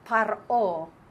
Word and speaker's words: Paro: Paro, that's how you pronounce Pharaoh in Hebrew.